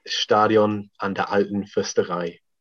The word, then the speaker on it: Stadion an der Alten Försterei: Do you just remind us how you actually pronounce it? – Stadion an der Alten Försterei.